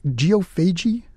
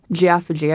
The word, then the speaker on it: geophagy: Professor, have I pronounced that correctly? Geophagy? – That's right. You could also say geophagy, I've heard it, tomato-tomato kind of thing.